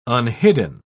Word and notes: unhidden: British English

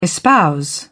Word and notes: espouse: British English

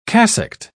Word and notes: cassocked: British English

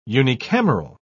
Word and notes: unicameral: British English

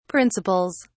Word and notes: principles: British English

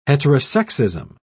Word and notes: heterosexism: British English

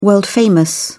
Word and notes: world famous: British English

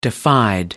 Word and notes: defied: British English

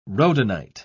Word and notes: rhodonite: British English

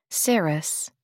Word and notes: serous: American English

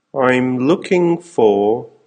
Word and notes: I'm looking for...: American English

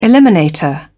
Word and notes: eliminator: British English

